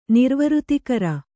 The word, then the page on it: ನಿರ್ವೃತಿಕರ: Kannada